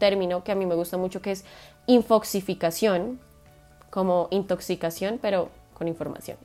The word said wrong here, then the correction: infoxicación: infoxicación, de hecho, creo que lo dije mal, infoxicación, intoxicación, infoxicación.